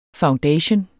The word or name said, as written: foundation